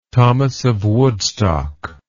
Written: thomas of woodstock

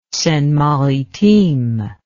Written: seine maritime

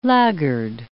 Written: laggard

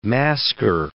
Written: masker

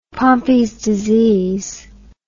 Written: pompes disease

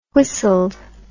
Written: whistled

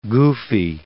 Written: goofy

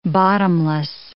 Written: bottomless

